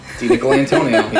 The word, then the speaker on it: DiNicolantonio: DiNicolantonio.